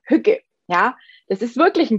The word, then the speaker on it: hygge: Ja, und der Begriff Hücke kommt aus dem Dänischen und wird Hücke ausgesprochen, also mit Ü. Es hat auch nichts mit hügelig zu tun oder so, sondern es wird wirklich, also es wird geschrieben H-Y-G-G-E und wird ausgesprochen wie H-Ü. G-G-E, ja, also Hücke.